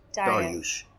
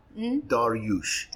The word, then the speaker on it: Dariush: Dariush. Dariush. – Dariush. – It's Iranian. Dariush. – I should have checked first. – Don't worry. Arabs say Darwish, but Dariush is a Persian king.